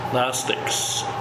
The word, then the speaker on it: Gnostics: How do you spell that again? – G-N-O-S-T-I-C-S. Gnostics. – Okay, say that once more. – G-N-O-S-T-I-C-S. – C-S. – That's Gnostics.